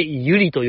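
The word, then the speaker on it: 悠理: 北川ゆりちゃんね、あの、あの子は。ゆうりと書いてゆりと読む。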